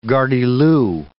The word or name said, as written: gardyloo